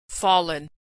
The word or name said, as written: fallen